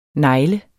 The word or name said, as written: negl